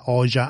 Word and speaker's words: Oxa: Prima era Un'emozione da poco di Anna Oxa, che poi tra l'altro in albanese, se non sbaglio, si pronuncia Oja, Anna Oja invece di Oxa.